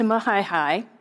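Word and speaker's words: Majayjay: But Ver, after he left the Bible school, went to Mahaihai. It's spelled like Majeje. That's what people usually say.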